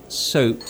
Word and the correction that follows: soup: It's pronounced soup. You wash with soap, but you eat soup.